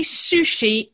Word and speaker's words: sushi: And now my curiosity, because how do you say sushi in Japanese? – Sushi. – Oh, sushi. So sushi. It's shorter. We say sushi, you know, but Japanese, it's sushi. – Yeah. The emphasis is on the final syllable.